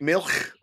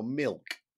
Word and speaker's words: Milch: how I should pronounce his name. – Right. – Was it Milch or Milch or Milk? And me and my producer, we went backwards and forwards for the length, 600 pages. Is it Milch, Milch or Milk? And Milch. and it just went, because generally the German pronunciations... Milch. They're all right, but it's just one name. And I think we ended up calling him Milk. – I've always called him Erhard Milk.